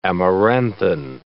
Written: amaranthine

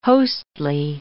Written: hostly

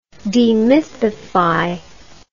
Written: demythification

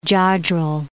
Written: Jodrell Bank

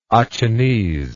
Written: Achinese

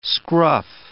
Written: scruff

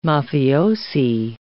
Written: mafiosi

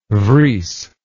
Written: vries

